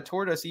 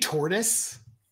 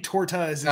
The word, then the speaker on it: torta: Did you just say tortoise? – I don't. How do you say it? – Torta. – Torta. But there's an S at the end. Is it a silent? – Well, he was saying tortas. – Oh, okay. Okay. Tortas.